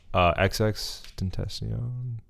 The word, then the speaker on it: XXXTentacion: XXXTentacion. – Is that how you pronounce it? It's like XX. – Yeah, yes. Triple X.